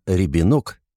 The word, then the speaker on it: Рябинок: Соня Рябинок, ударение на последний слог, девушка бойкая и деловая.